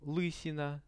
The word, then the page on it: lysina: Russian